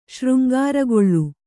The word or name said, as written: ಶೃಂಗಾರಗೊಳ್ಳು